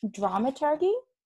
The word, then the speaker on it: dramaturg: I do want to touch base because I feel like I'm going to get this dramaturgy. No, is that how you say it? – Dramaturgy, soft G. And then if you say you are a dramaturg, it's a hard G, or dramaturge.